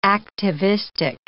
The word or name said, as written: activistic